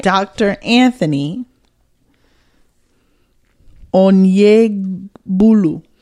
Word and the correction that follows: Onyegbula: Bula. I'm sorry. Not Bulu. Onyegbula. – That's pretty good, sis. – Onyegbula.